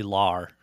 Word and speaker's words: Lahr: Lar. It's Billy Lahr.